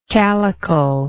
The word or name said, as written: calicle